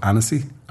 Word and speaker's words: Annecy: but I was in a place called Annecy,